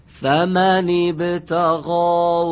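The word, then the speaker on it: فمن ابتغى: باز هم درست و خوب تلفظ نشد. – فَمَنِ بَتَغَا، همین کلمه رو بگید الان. – فَمَنِ بَتَغَا. – آفرین، الان خیلی خوب بود.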